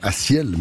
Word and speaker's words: A6EL: Il vient de Renan, il a 20 ans, il faut prononcer son nom Assiel, même si s'écrit A6EL.